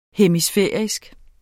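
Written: hemisfærisk

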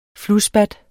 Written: flusspat

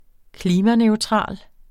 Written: klimaneutral

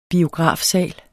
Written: BIOGRAFSAL

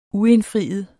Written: uindfriet

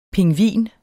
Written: pingvin

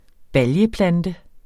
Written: BALJE

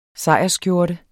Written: sejrsskjorte

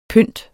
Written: pynt

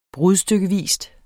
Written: brudstykkevis

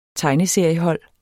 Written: tegneseriehold

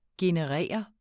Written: generere

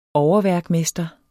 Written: overværkmester

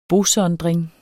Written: bosondring

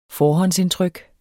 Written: forhåndsindtryk

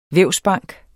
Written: vævsbank

